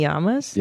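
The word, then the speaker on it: llama: I have many yamas? – Yeah. – I guess that's a good thing. – Yeah. Your llamas, right? How do you say it? – It's yama. That's how I pronounce it.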